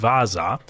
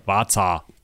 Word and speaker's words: Vasa: Ja, und zwar sprechen wir über die Vasa oder historisch auch Vasa mit W, also eigentlich schreibt man das mit V-A-S-A. Also das ist so ein bisschen das Ding. Also es ist benannt nach dem Haus Vasa oder auch Vaza, polnisch. Das